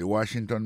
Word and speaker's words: Washington: Si on a mis accent sur accent encore, on va que mot Washington, là, accent tombé sur le premier syllabe là. Pour mot monument, il tombé sur le premier syllabe là, tout. The Washington Monument.